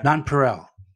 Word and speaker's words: nonpareil: Did I pronounce it correctly? Nonpareil? Is that how it's pronounced? – Wow. Now you're here. I'll play it. See if it. Oh, it's in my headphones. Nonpareil is what they say.